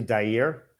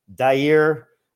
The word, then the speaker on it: Dair: No, the technical pronunciation is actually Dare.